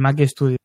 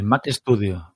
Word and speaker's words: Mac Studio: Pero el, el, el, Max Studio, ¿cómo es? Sí, ¿no? El Mac Studio. El Mac Studio, Mac Studio, sí, sí, sí, sí.